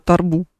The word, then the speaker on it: торбу: Ты знаешь, что у меня была рубрика про торбу? Или торбу? – Торбу. Торбу, конечно, торбу, да.